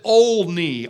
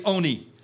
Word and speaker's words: Olney: He was a very well-known godly pastor, first in a small town in central England called, well, we would pronounce it Olney, O-L-N-E-Y. But if you talk to people there, they say Oney.